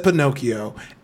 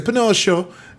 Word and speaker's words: Pinocchio: I don't know who did this, but I said Pinocchio, and someone was like, "You don't pronounce it like that in Italian." So now I would say Pinocchio. Yeah, I don't know. I don't know. – Literally Italian, always like two.